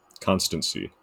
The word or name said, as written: constancy